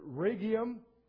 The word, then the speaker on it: Rhegium: The Rhegium is the next one, emphasis on the first syllable.